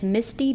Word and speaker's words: Misti: It's Misty B. Writes. My name is spelled M-I-S-T-I.